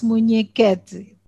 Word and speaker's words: Munhequete: Actually, you pronounce it well. It's Munhequete. It's okay. Right.